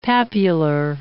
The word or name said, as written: papular